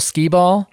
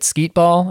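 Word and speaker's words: Skee-Ball: My dad has built many different games that are designed to be played with beanbags, including one that he calls cornhole skee ball. – Yep. – Except he keeps pronouncing it skeet ball. And I'm like, nope.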